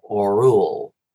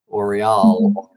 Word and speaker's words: Orual: So he basically tells the story through the eyes of the oldest sister, who's named Oriol, O-R-U-A-L, Orul, Oriol, Orul. I've heard it pronounced different ways.